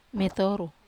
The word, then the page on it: metoru: Wamesa